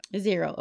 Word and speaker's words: Xero: Wave spelled W-A-V-E apps. And then Xero is actually X-E-R-O. Sorry. I think it's pronounced Xero. That's what I call it, y'all. Zero.